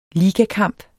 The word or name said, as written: ligakamp